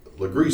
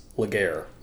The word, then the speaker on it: Legare: And so one we decided to do was Legree Street. – Or colloquially known by outsiders as Laguerre. – Laguerre. So Laguerre is spelled L-E-G-A-R-E, but it is pronounced Legree. So like L-E-G-R-E-E.